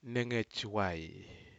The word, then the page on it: negejwai: Aneityum